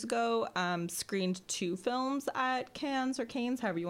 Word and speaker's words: Cannes: Kane clicks no for a second year. I'm saying that wrong. Cannes. The French Film Festival. I'm not saying it with the French accent.